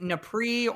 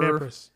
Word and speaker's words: Nepris: I've heard it as like NEPRI or NEPRIS. – There you go, NEPRIS. – There you go. – NEPRIS. Okay. Yeah. I think that was like the French pronunciation coming out of me.